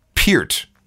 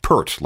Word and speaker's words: Peart: But before we get into the record, this would be a good time to confirm the pronunciation of Neil's last name. It is, in fact, Peart, not Pert, like the shampoo.